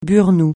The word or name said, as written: burnous